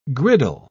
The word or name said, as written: griddle